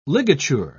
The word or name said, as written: ligature